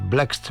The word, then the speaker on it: Blxst: Donc, c'est Blext, tu vois, ça s'écrit B-L-X-S-T. Enfin, aucune voyelle, mes chers amis. Donc, quand il n'y a pas de voyelle, c'est un peu compliqué à prononcer.